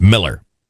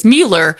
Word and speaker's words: Mueller: But I initially thought it was Mueller, but he beat it into my head that it's Miller.